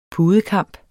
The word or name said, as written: pudekamp